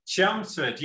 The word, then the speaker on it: Chelmsford: Through some research, I found out that you went to high school in Chelmsford, just outside of London. I don't know if I'm pronouncing that right. Did you grow up there? – Chelmsford, yeah, absolutely.